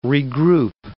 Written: regroup

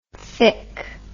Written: fic

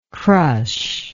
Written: crushability

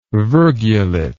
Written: virgulate

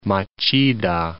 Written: Machida

